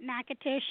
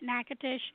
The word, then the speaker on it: Natchitoches: I think it's been that way for probably close to 300 years now, but I've always pronounced it Natchitoches, and all the locals pronounce it Natchitoches,